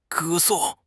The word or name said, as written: 歌颂